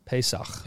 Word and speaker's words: Pesach: Pesach.